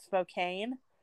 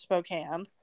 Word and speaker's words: Spokane: But it's just like Spokane, Washington. When people say it, when they read it and they say it out loud, they pronounce it Spokane. But it's pronounced Spokane.